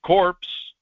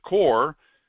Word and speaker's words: Corps: Corpse is a body, a dead body, actually, corpse, but core, C-O-R-P, is a French word that means body. Core. Like in Marine Corps. Of course, some idiots cannot pronounce it corp because the P is silent in French.